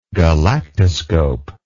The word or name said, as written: galactoscope